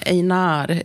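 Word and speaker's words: Einár: Einar, är det så det uttalas? – Han har accent över A, så att jag har lärt mig det nu.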